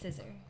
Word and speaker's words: SZA: Asked how to pronounce that. – It's SZA.